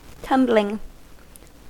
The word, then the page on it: tumbling: American English